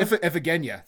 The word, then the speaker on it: Iphigenia: Iphigenia? I don't know if I'm saying that right. – Yeah, yeah. Iphigenia? – Iphigenia. There you go, yeah.